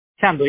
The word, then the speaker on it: Sandhu: Am I pronouncing your last name correctly? Is Sandhu? – Sandhu, yes. A-N-D-U. – Beautiful. Okay. – S-A-O-R-I-N.